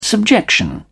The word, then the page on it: subjection: British English